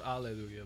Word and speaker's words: Alleluia: It's actually pronounced Alleluia, by the way. – Alleluia? – That's the actual correct pronunciation that I learned.